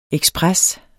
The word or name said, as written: EKSPRESSEN